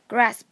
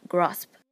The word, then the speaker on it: grasp: Grasp 它的美音读成 grasp，英音中则读成 grasp。